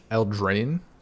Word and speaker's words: Eldraine: Eldraine.